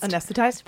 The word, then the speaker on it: anesthetized: In modern ECT, though, the patient is completely, I can't pronounce this word, anesthetized. – Anesthetized? – That's the word, yes.